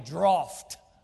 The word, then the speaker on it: draught: As Travis told me this morning, the correct pronunciation is actually droft. But there's no F there, but you can look it up.